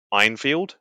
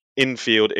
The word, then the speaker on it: Enfield: And the guy, every time he talks about the British, the rifle, the Enfield, it pisses me off because he pronounces it as Einfield. – And it's like, there's no I in Enfield except for the field, not in the N.